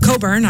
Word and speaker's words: Cockburn: In this final hour for me, I got music from, let's see, Dentist, Bruce Cockburn is coming up. Coburn, I always say that wrong, and they always yell at me for that.